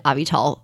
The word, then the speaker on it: Avital: I sort of tell people it's a combination of Advil and Tylenol, but it's spelled like a vital, like a vital organ or a vital source of information. So A-V-I-T-A-L.